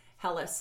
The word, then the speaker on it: Helles: Helles, spelled H-E-L-L-E-S, pronounced H-E-L-L-U-S.